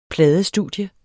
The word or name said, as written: pladestudie